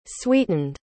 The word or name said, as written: sweetened